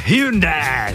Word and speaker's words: Hyundai: vyladěný Hyundai Tucson. – To se vyslovuje jinak. – Je to tak. – Hyundai, jak v té reklamě, Hyundai.